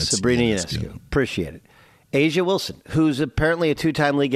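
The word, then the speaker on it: A'ja Wilson: Is it Ajay Wilson? I don't know. – Asia Wilson. – Asia Wilson. I'm sorry.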